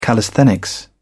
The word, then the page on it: callisthenics: British English